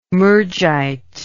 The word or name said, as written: Murjite